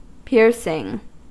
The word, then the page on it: piercing: American English